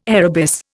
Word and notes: arabis: Indian

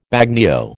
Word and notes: bagnio: American English

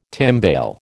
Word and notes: timbale: American English

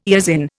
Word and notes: eosin: Indian

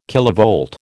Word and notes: kilovolt: American English

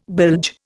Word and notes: bilge: Indian

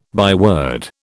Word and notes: byword: British English

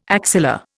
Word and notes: axilla: Indian

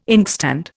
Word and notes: inkstand: Indian

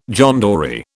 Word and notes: John Dory: British English

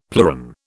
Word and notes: pleuron: British English

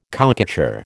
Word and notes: cowcatcher: American English

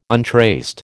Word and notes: untraced: American English

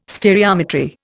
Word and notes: stereometry: Indian

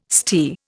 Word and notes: stere: Indian

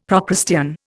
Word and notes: Procrustean: Indian

